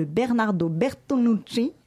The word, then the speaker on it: Bernardo Bertolucci: Bernardo Bertolucci.